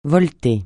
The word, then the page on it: voleter: francais